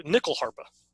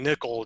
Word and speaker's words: nyckelharpa: Uh, it's an instrument called the nyckelharpa. I'm, I'm saying nickel. It's it's a Y. It may be pronounced as a U, so it may more be more like knuckle harpa, but I, I don't know.